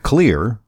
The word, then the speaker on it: Xlear: He's the Spry brand manager at Clear. That's spelled X-L-E-A-R.